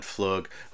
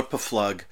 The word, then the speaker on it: Pflug: Oh, I know I call her Paflug. I look at it this way. Her name is spelled P-F-L-U-G, and we've been told over and over again that it is Flug or Flug.